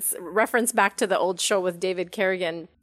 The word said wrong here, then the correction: Carradine: or Carradine, I think. I'm pronouncing that wrong. – That's right, David Carradine. – Carradine, that's right.